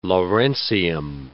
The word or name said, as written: lawrencium